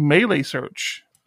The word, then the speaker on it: Meilisearch: And so I had found Melee search.